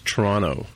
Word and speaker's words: Toronto: Well, it's it's not Toronto. Toronto. It's Toronto. Toronto. We kind of we kind of roll over the last T, right? Oh, okay. Like Rono. Toronto.